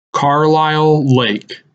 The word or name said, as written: Carlyle Lake